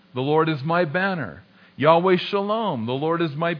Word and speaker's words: Yahweh: And this name, I am, of course we pronounce it in English, Yahweh. Some pronounce it Jehovah. Yahweh is probably a better guess at how it's actually pronounced.